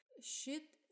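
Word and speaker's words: Schitt: Шит э, — это фамилия основателя города и текущего мэра, но она произносится как и э, одноименное, ну как бы как и слово дерьмо на английском.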